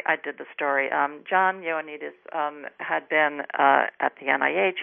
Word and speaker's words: Ioannidis: Your article highlights the work of a specific physician, and I'll probably mispronounce his last name, Dr. John Ioannidis. – Ioannidis, very close. – Okay, Ioannidis.